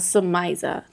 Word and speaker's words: Samyaza: Book of Giants, Samyaza. Am I saying that right? – Samyaza? – Samyaza, thank you.